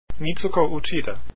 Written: Uchida

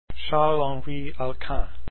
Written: Alkan

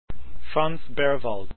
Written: Berwald

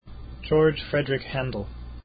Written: Handel

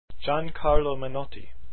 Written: Menotti

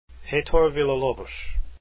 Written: Villalobos

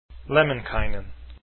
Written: Lemminkainen

